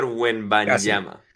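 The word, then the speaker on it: Wembanyama: Ves ahora el novato este, a Wenbayamba, lo pronuncié bien, ¿no? – casi, Víctor Wenbayamba.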